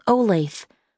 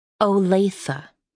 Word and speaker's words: Olathe: And it's from a place called Olathe, said Ada, spending two syllables on the name. – Olathe, said Miss Holmes, if I'm not mistaken.